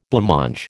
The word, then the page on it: blancmange: American English